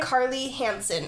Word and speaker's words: Carly Hansen: How do you say her name? – Carly Hansen. – Oh, just like a normal person.